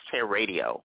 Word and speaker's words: Haiiradio: It's pronounced Hair Radio, but remember it only has one R in the middle, and it plays around the clock, so you can tune in any old time.